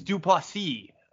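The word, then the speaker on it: Du Plessis: Except for Dracus Duplessis, who, by the way, I'm pretty sure we continue to say his name wrong. It's Duplessis.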